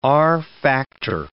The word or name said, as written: R factor